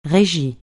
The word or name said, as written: régie